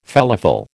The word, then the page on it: felafel: American English